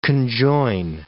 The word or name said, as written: conjoin